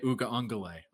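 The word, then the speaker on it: Uiagalelei: And I asked before we went on the show, I'm like, how do you pronounce his name? And he's like, DJ Uga-Ungale.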